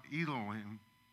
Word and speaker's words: Elohim: And one of them is pronounced Elohim, and the other one is pronounced Yahweh.